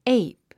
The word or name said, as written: ape